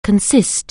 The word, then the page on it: consist: anglais